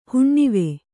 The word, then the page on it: ಹುಣ್ಣಿವೆ: huṇṇive